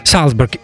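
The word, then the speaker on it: Salsberg: Нейтан Салсберг с фамилией, которая пишется как Зальцбург. Ну, я не думаю, что она так произносится, поэтому Нейтан Салсберг.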